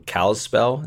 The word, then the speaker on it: Kalispell: We have some family down in Kalispell. Is that how you say it? – Yep.